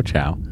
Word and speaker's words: Chao: It's, I know Rosalind Chow? C-H-A-O? How would you say that? – C-H-A-O, Chow.